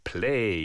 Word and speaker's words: Plej: Also, Play, yeah, of course, intriguing spelling, as always in the acid jazz, P-L-E-J, yeah, but pronounced play.